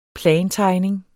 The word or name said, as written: plantegning